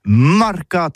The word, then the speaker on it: mercat: Eh bien, dis ça à tous, le mercat de l'Uni ou le mercat des rebelles, mais en gascogne toulousain, c'est prononcé sur les marcats, avec un A marcat. En gascogne toulousaine, du côté par exemple de l'île des Baches, c'est l'île Jourdain, pour parler du marché, vous prononcez les marcats.